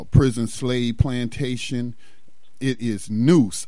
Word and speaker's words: Neuse: But it's spelled it's spelled N-E-U-S-E. So I would presume to pronounce that as Noose.